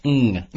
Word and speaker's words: hang: Hang up hang. On. Yeah, the NG you want to pronounce. Ng.